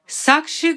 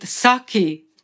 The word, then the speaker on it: Sakshi: They are also pastimes of the Lord, connected to Sakshi Gopal, or in Bengal it's told Sakhi Gopal, Sakhi or Sakshi Gopal. In Bengali language, that sh sound is not pronounced. So instead of Sakshi in Sanskrit, in Hindi, it's Sakhi.